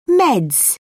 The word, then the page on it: meds: British English